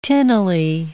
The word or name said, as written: tinnily